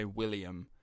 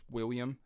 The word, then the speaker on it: William: Two L's almost is not pronounced. Like, we'd say William, and in Scotland, it's just William. It's almost like you don't say it. It's like a Y. Two L's become almost like a Y, like William.